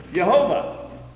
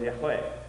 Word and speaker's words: Yahweh: And if you go back now we go back and say, well, what was the real pronunciation? And so forth, it was Yahweh.